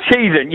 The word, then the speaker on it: Cheshunt: It's Cheshunt, yeah. Cheshunt, not Cheshunt. – Sorry. – You've got every name wrong. Don't worry, you've got it wrong all day. – Sorry, it's pronounced Cheshunt.